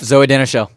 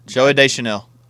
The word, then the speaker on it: Zooey Deschanel: Zooey Deschanel. – Zooey Deschanel.